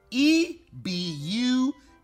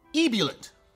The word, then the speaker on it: ebullient: Pronounce this word: ebullient. Ebulliant. Ebulliant. I'm sorry. Ebulliant. – Both are incorrect. Ebullient.